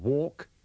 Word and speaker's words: walk: In these sentences, pay attention to the pronunciation of walk and walking. Don't pronounce the L.